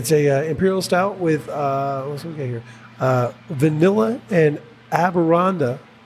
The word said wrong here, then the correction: Amburana: No, excuse me. I pronounced that wrong. Sorry. – Ashwagandha. – Excuse me. Amburana wood.